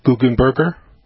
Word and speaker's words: Guggenberger: Well, my name is Gary Guggenberger. It's break it down in syllables. It's pretty easy.